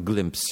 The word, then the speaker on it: GLMPS: Ale, ale pojawił się fajny program, Glimps się nazywa, czy to przynajmniej inaczej, tak się czyta, pisze się GLMPS.